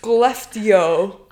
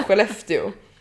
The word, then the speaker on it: Skellefteå: Att han säger så här Skellefteå. Man ska säga Skellefteå. Vi säger att han är Mikael Blomqvist och vi bara ah, please, fan.